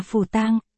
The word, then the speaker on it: Phù Tang: Hai chữ này phát âm là Phù Tang, đó là tên mới của nàng.